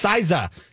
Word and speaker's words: SZA: How do I spell it? S-A-I? – No, S-Z-A. – Oh, that's Siza. That's who we've been playing on, I think.